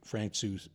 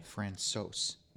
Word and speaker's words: Francouz: He was brought up, and in normal fashion, they ask him how that he likes his name pronounced, any nicknames he likes, whatever else. And he said it's Pavel Francois. So, Francois.